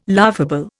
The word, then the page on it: laughable: Indian